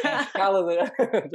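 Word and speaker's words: Kalajdžić: I was waiting for Irfan to come in and say, oh, Kalajic. – No, I think you were spot on.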